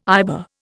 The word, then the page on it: IBA: Indian